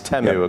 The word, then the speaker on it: Temu: And this year they pronounced it in the ad, and a lot of people were surprised that I'd been saying Temu. It's Temu, according to the ads that we saw last night.